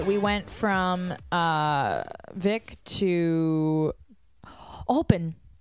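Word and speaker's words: Höfn: So I can't remember how it came up, but I was talking to a woman at a hotel saying, we're going to this place called H-O-F-N, Hoffen. She goes, oh, it's actually pronounced open, like open. Open.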